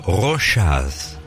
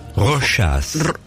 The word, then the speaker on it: Rochas: Bueno, Rochas. Rochas.